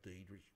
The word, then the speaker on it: Diedrich: So my name is Brad Diedrich, which is spelled BRADDIEDRICH. – Wait a minute, again, DIEDRICH. Yes. It spells died rich.